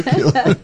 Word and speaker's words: Keel: Surname is Keel. And I pronounced it incorrectly. – It's okay. – many people do. – It's like Neil Keel. There you go. Neil Keel.